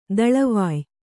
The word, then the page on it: ದಳವಾಯಿ: daḷavāyi